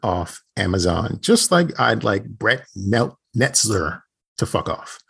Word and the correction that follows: Netzer: Pretty much saying his name wrong, but it's Brett Netzer.